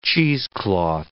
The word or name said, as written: cheesecloth